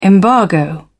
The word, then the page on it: embargo: British English